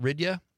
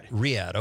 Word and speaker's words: Riyadh: Is it Ridya? – Riyadh. – Okay. I was looking at it phonetically.